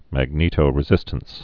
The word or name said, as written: magnetoresistance